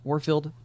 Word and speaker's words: Warfield: Oh, did y'all love when they take over when the Daily Planet kicks out, is it Warford Publications? I can't say his name. – Warfield Publications. – Warfield.